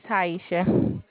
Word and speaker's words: Taeichia: It's Taisha. – How do you spell that? – T-A-E-I-C-H-I-A.